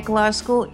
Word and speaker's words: Glasgow: I presume you pronounce it Glasgow, not Glasgow. Welcome. – Yeah, Glasgow.